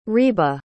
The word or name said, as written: riba